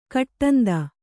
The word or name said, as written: ಕೊಡಂದೆ